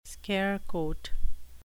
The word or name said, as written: scare quote